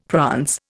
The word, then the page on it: prance: Indian